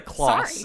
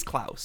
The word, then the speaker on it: Klaus: Just also, how do you say Klaus? – Klaus? Is it Klaus? – Okay, because you've been saying like Klaus. Sorry. It's Klaus.